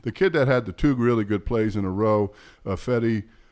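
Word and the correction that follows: Ifeadi: Odenebo. Is that how you say it? – Afadi. – Yeah, Afadi Odenebo.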